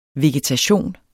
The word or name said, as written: vegetation